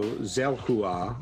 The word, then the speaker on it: Xelhua: Because I think the X is represented as like maybe a Z, like in xylophone maybe, or even an S sound. But what's the spelling for that? – It is X E L H U A. So Zelhua or Zelhua.